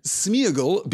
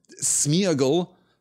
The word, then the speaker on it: Sméagol: the accent on the E tells us that that is supposed to be a double vowel sound. It is not supposed to be united into a single vowel sound, but Sméagol feels weird. It feels ungainly. It feels, I think, less elegant and less direct. So generally speaking, it is contracted to Sméagol.